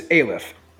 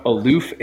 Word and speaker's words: Aleph: Yeah, his name is Aluf. A L E P H, not Aloof, A L O O F.